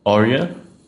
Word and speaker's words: Aurier: Saying it right. Aurier? That's it. – Aurier.